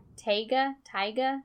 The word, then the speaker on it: taiga: Oh, how do you say this? Taiga? It's T-A-I-G-A, which is basically just swampy boreal forests.